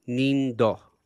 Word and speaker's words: Inindo: Inindo. – I was trying to avoid Asian accents in this episode. Thank you very much. – It's not an Asian accent if you're pronouncing the Japanese word.